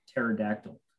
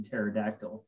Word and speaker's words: pterodactyl: So I, I mean, I, I think one of the, the, um, you know, it's kind of like pterodactyl. I, there's like that silent P in pterodactyl for those of you that don't know.